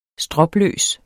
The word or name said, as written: stropløs